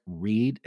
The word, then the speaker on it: Read: The prosecutor in the case, if you watch the documentary, and by the way, her name is spelled R-E-A-D, just like Reed, as opposed to normal Reed, which might be R-E-I-D. This is R-E-A-D, so if you're Googling her and want to look her up, it's Karen Reed, R-E-A-D.